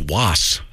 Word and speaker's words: Waas: His last name is spelled W-A-A-S. – Les Wouldn't that be Wass? Les Wass? Wass?